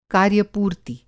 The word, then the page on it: कार्यपूर्ति: कार्य पूर्ति